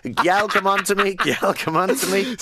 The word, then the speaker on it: gyal: And gyal is spelled G-Y-A-L. It's gyal, come on to me.